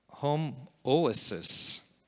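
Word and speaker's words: homoousios: It's H-O-M-O-O-U-S-I-O-S. Homoousis, and it is this.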